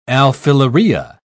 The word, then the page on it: alfilaria: British English